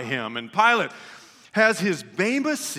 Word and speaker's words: Bema: The Bema seat is what we've always called it, but those who are Greek scholars pronounce it correctly, Bema. It's actually correctly pronounced the Bema seat, except if you live in the south part of our country, everybody just says Bama.